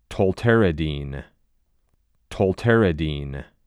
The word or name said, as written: Tolterodine